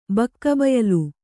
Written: ಬಕ್ಕಬಯಲು

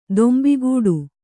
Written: ತುಂಬುಗೆಡು

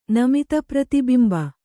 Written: ನಮಿತಪ್ರತಿಬಿಂಬ

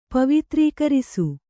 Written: ಪವಿತ್ರೀಕರಿಸು

